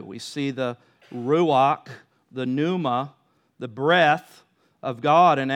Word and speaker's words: pneuma: and that's the word pneuma, P N E, in, in the way it's written, uh, P N E U M A. The P is silent, obviously, but that's uh, the word pneuma, uh, when we study the doctrine of the Holy Spirit, we study pneumatology, all right?